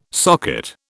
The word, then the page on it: socket: British English